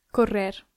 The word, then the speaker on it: correr: Correr. – Correr. So you have to roll that R. It's a double R.